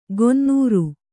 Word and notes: ಗೊನ್ನೂರು: gonnūru

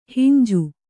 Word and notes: ಹುಂಜಿ: Kannada

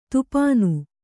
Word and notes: ಥಾಪನೆ: Kannada

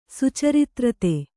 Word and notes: ಸುಚರಿತ್ರತೆ: Kannada

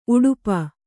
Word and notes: ಉಡುಪ: uḍupa

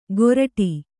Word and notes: ಕೋರಡಿ: kōraḍi

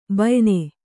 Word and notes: ಬಾಯಿನ: kannada

